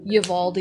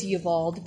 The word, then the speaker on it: Uvalde: Ever since the Uvalde, I learned how to say that correctly. Uvalde. I thought it was Uvalde, but in Uvalde, Texas, where I think it was 19 children were shot and killed.